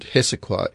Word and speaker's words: Hessequa: And that koi word, Hessequa, is spelled H-E-S-S-E-Q-U-A, Hessequa Harmony.